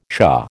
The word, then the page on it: shah: American English